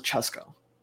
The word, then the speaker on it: Cesco: So my full name is Francesco, but when you shorten it, you pronounce it Cesco.